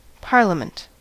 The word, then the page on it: parliament: /ˈpɑɹ.lə.mənt/; /ˈpɑː.lə.mənt/